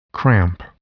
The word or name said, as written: cramp